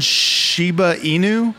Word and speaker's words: Shiba Inu: She has one daughter, Maggie Sue, and one Shiba Inu.